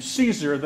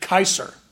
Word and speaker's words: Caesar: Well, So the Romans would not have pronounced that name Caesar. They would have pronounced it Kaiser.